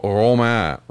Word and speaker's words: Romain: But then when he made his next reference, he buried the R, which is the correct French pronunciation. It's more of a, it's more of kind of a throat, Romain, right? It's not a ro, it's a ro.